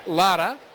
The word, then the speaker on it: Lara: And we're saying Lotta, but it's spelled L-A-R-A.